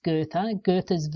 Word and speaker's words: Goethe: So he starts with, and this is something that was mentioned in the introduction about, now I think it's pronounced Goethe's Werther.